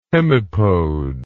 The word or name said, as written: hemipode